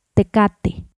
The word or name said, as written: Tecate